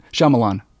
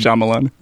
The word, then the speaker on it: Shyamalan: Shyamalan. – Is it pronounced Shyamalan? – Yeah. – I didn't want to. I was trying to be fancy. – It's Shyamalan.